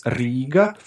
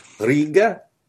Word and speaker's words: Riga: It's just actually it's with a long I. It's Riga. – Riga. – Riga. – Riga. – Yeah, but harder R. – Riga.